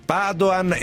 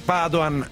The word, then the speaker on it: Padoan: E a proposito del ministro dell'economia, fatemi rispondere ai tanti ascoltatori che ogni volta ci scrivono: si pronuncia Padoan? No, lui stesso lo pronuncia Padoan e ce lo ha confermato su Twitter qualche giorno fa il suo portavoce. Quindi chiudiamola qua, è Padoan e il traffico.